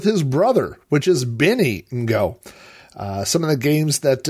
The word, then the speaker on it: Ngo: And I have to say this, I'm very sensitive to trying to pronounce people's name correctly, and Tony's last name is spelled N-G-O, and I looked up online, and the last name N-G-O, the first two letters N-G are pronounced like the end of the English word wrong, so you have that N-G, and so I believe the correct pronunciation is Tony Ngo.